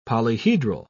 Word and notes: polyhedral: British English

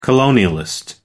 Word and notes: colonialist: American English

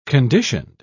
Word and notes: conditioned: British English

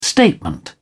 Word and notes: statement: British English

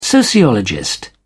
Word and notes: sociologist: British English

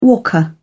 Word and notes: walker: British English

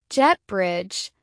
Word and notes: jet bridge: American English

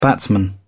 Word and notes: batsman: British English

